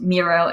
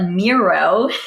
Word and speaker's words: Miro: I there's both Miro and Mural out there. I'm nothing against Mural, but Miro rhymes with hero.